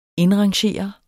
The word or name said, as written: indrangere